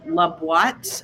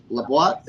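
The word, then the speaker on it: La Boîte: The best blends are from a guy in New York called La Boite. It's spelled like L-A-B-O-I-T-E, like the box in French.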